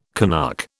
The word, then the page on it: Canuck: British English